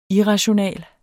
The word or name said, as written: irrational